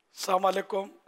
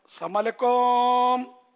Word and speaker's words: السلام علیکم: سلام کا تلفظ بھی درست نہیں ہوتا، یہ کئی بار میں سمجھاتا رہتا ہوں، السلام علیکم، السلام علیکم اس طرح کر رہے ہوتے ہیں،